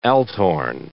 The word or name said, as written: althorn